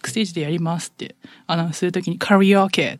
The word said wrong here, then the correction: カラオケ: って言っちゃって、普通にその場で、あれなんで、なんか、 Why am I pronouncing this in English? とか、カラオケって言うんだよとかって言って。